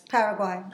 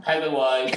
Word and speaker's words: Paraguay: Paraguay. – Paraguay.